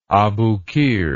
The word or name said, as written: aboukir